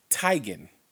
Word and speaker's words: Teigen: The way you pronounce her actual last name, though, is not Teigen, it's Teigen. – Teigen? – Like bow tie. So it's Chrissy Teigen. – Is it spelled T-E-I or T-E-E? – It's spelled T-E-I-G-E-N.